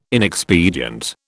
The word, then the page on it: inexpedient: British English